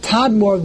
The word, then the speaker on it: Tadmor: So that's why it's spelled Tamur, and that's why the way it's spelled is Tamur. But we read it Tadmor, the real name of the country.